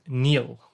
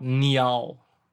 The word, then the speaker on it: Niall: I guess my anglicized way of saying it is Neil, like N-E-I-L. But my gran, who's from the north of Scotland, or was, God bless her cotton socks, who was from the north of Scotland, she would call me growing up Niall. So that would be technically the correct pronunciation of N-I-A-L-L.